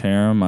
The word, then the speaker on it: Herum: Is that how you pronounce it? – Yep. – Okay. Sorry if I Herum, that's it. – Herum, okay. Yeah.